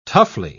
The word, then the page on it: toughly: British English